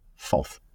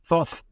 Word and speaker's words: Thoth: Thoth, of course, is in Greek pronunciation of Tuth or Tut. And you'll recognize that sound from Tutankhamun. So, in a way, if you were to say Tutankhamun in Greek, it would start with Thoth Ankhamun.